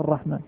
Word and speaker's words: الرحمن: ما في ألف. طيب لكن هناك ألف وهي ألف تكتب يسموها ألف خنجرية صغيرة، ولكن لما كثر استعمال الرحمن بدون الألف عرفها الناس، كتبت ألف، ما كتبت ألف تنطق الرحمن.